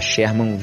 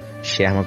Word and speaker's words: Sherman: Tá Sherman Viana. Sherman com SH. Sherman Viana.